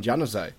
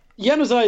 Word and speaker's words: Januzaj: Yeah, 2 goals in injury time in the first half, both on assists by Adnan Januzaj. – That's a soft J. You got to learn when to soft J and when to hard J.